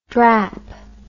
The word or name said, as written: drap